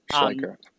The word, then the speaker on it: Schleicher: Schleicher.